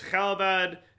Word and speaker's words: Calibad: That is the pronunciation. It's Calibad. It's Calibad, like that.